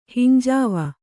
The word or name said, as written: ಹಿಂಜಾವ